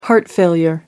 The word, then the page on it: heart failure: American English